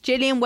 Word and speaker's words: Jileon: Is it Jillion? J-I-L-E-O-N. Is that how I say it? – Yep. – Jillion wellies do loads of really fun designs and they are specifically a plus size welly brand.